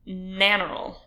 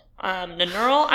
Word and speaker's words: Nannerl: Anyway, her name was Maria Anna, although she was nicknamed Nannerl. Nannerl? I'm not sure how you say it. I looked it up, and it said that you put the emphasis on the first syllable, which would make it...